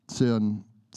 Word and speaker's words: Seufzen: Aber das, der Geist selbst trifft für uns in ein mit äh, unaussprechlichen äh, Säuferen. Seufzen, Seufzen, Seufzen, der aber die Herzen erfrischt, weiß, was äh, das Tragen des Geistes ist.